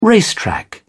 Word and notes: racetrack: British English

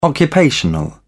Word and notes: occupational: British English

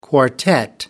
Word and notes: quartet: American English

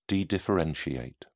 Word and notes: dedifferentiate: British English

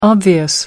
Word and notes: obvious: American English

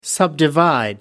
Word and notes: subdivide: American English

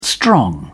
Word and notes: strong: British English

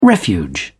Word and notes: refuge: British English

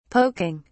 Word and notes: poking: British English

